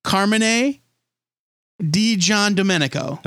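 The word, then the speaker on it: Carmine Di Giandomenico: And they pronounced his name on the video, and I'm going to try to recreate it because we've been pronouncing it wrong for 5 years. Carmine DiGiandomenico. – Okay.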